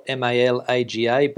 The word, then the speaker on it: Malaga: There's a suburb called it's spelled Malaga, but we pronounce it down there as Malaga.